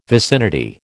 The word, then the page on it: vicinity: American English